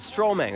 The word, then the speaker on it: Stromae: It's called Stromae. Is that right? Did I say it right? Yeah, okay, he's giving me the okay. You can say yes. Did I say it right? – Yes.